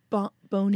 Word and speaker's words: Bon Iver: And how do you say his name? – Bon Iver. – That's good.